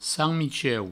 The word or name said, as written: sant-Micheu